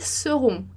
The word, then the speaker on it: seront: Então, presta atenção lá que esse E não é acentuado, ele é mudo, né? E a gente vai falar elles seront, seront, tá? Então, veja que não é seront, Essa é a nuance aí que você tem que captar.